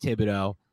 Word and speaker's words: Thibodeaux: Yeah, Kayvon Thibodeaux, the Oregon I didn't, pass yeah, You know I'm bad. Yeah, of course, of course. And you know I'm bad with names, but if they can get Thibodeaux, I feel better, and hopefully they can find like another Andrew Thomas.